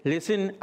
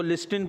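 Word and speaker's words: listen: लिसन, आपने सुना होगा लिसन बोलते ना, उसकी स्पेलिंग में अंदर क्या आता है, टी आता है, हाँ, लेकिन उसको लिस्टिन पढ़ते हैं क्या? टी साइलेंट होता है।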